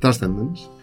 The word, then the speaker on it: Transcendence: Transcendence. Ahí está, ahí sí es de Janet. ¿Cómo se pronuncia? – Transcendence.